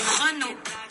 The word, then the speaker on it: Renault: Esta marca de coche en francés se pronuncia Renault.